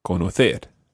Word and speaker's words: conocer: All the infinitives always are stressed on that last syllable. So it's saber, conocer.